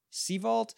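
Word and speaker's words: Sevault: Sevalt? It's Seven and Vault mixed together into one word.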